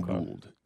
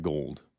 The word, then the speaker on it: Gould: A little bit undersized, but Anthony Gould. G-O-U-L-D, Gould, not Gold.